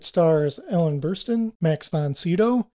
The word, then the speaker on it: Sydow: That's the way I'm going to pronounce his last name because everybody says it different. And I had a friend in elementary school whose last name was Sydow. And he's like, oh yeah, Max von Sydow is a relative of ours.